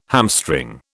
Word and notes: hamstring: British English

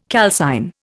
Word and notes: calcine: Indian

